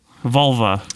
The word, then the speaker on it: vulva: So we are back with our series on Volve. Am I pronouncing that right? – Vulva. – Yes.